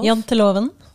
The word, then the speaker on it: Janteloven: Yeah, Janteloven. How do you say it? Jantelov? – Janteloven. – Janteloven.